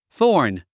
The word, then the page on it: thorn: θɔ́ː r n; ソーン